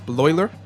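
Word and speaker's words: Bleuler: Eugen Bloiler. – Good. – Bloiler. – Good, Bloiler. – Good try there. – That's actually how you pronounce it. I speak a little bit of German.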